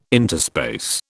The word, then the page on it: interspace: British English